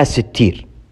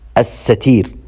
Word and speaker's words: الستير: يقول الشيخ محمد بن صالح العثيمين رحمه الله في شرح القواعد المثلى: الصواب هو أن اسم الله الستير نطقه بفتح السين وكسر التاء المخففة على وزن السميع والبصير، وليس بكسر السين وتشديد التاء المكسورة.